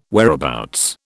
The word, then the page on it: whereabouts: British English